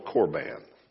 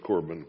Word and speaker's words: korban: And what he's talking about in that illustration there about mother and father is what they called korban. That's the way I pronounce it. It may be something else, but I say korban because it's saying Corbin confuses me.